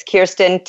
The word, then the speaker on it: Keirsten: Facebook, it's Kirsten Talon, KEIRSTEN, Talon, TALLON, and the same with LinkedIn.